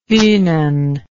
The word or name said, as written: funen